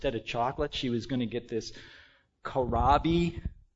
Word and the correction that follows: Carob: Carob. That's it? Okay. That's how you pronounce it. Thank you. Carob. I got corrected.